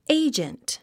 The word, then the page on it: agent: éidʒənt; エイジェント